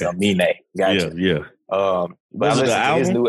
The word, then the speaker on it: Aminé: It's Aminé. I listened to his new album. – Anime? Is that his name? – It's Aminé.